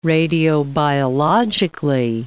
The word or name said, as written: radiobiologically